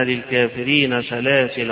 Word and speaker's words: سلاسل: فهتلاقي في المصاحف اللي في أيدينا سلاسل لام ألف، والألف عليها سكون، دائرة مقفولة معناها إنها لا تنطق. يبقى معنى ذلك في الوصل وفي الوقف هنقرأ إن أعتذنا للكافرين سلاسل وأغلالا وسعيرا، إذا وقفت عليها إن أعتذنا للكافرين سلاسل، إن أعتذنا للكافرين سلاسل.